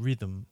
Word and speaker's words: rhythm: I also want to catch you there because of the way the word rhythm, I think you're pronouncing that sounds a bit like Richmond. because the T is a ch sound in Portuguese. – Yeah. – But you're saying rhythm.